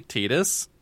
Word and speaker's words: Tidus: Which she's pronounced it. It's actually Tidus. The Japanese name is Tida, which would make it Tidus, because they would just drop the S.